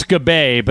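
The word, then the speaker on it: Gabbai: G-A-B-B-A-I. I hope I'm pronouncing that correctly. – It's Gabay, but go ahead.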